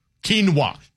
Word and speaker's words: quinoa: Q-U-I-N-O-A.